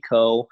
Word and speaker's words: Koh: Coe, and the last name is spelled K-O-H. So James D. Coe.